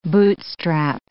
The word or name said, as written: bootstrap